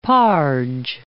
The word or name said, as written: parge